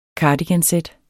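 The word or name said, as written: CARDIGANSÆT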